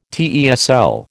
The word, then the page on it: TESL: American English